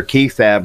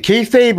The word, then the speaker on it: kayfabe: Yeah, it's technically kayfabe, but we can use kayfab.